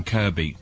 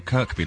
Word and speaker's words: Kirkby: It's Kirby Lonsdale, not Kirkby Lonsdale. – Yeah. Why did you say Kirkby? – Because it's spelt Kirkby, right? – As in, as is the place in Merseyside spelt Kirkby, but pronounced Kirby.